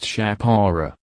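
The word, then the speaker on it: Chapora: Portuguese rechristened it Chaparro, pronounced Chapara.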